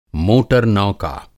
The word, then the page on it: मोटर नौका: Hindi